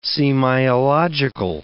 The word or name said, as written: semiological